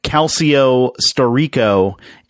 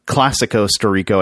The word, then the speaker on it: Calcio Storico: Calcio Storico is how it's pronounced, not Classico Storico.